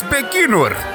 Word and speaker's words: Baikonur: The Russian space agency showed a Proton M rocket carrying the Spectre RG taking off from the launch pad and how do you say it? Baikonur? Baikonur. In Kazakhstan. – It's Baikonur.